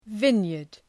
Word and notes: vineyard: ‘vınjərd

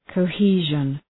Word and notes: cohesion: kəʋ’hi:ʒən

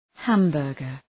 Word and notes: hamburger: ‘hæm,bɜ:rgər

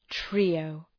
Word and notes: trio: ‘tri:əʋ

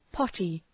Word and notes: potty: ‘pɒtı

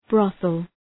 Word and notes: brothel: ‘brɒɵəl

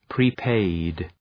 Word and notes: prepaid: prı’peıd

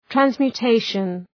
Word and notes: transmutation: ,trænzmju:’teıʃən, ,trænsmju:’teıʃən

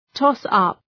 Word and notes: toss-up: ‘tɒs,ʌp, ‘tɔ:s,ʌp

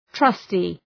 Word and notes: trusty: ‘trʌstı

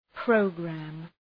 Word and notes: program: ‘prəʋgræm

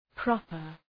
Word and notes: proper: ‘prɒpər

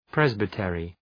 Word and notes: presbytery: ‘prezbı,terı

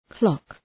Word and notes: clock: klɒk

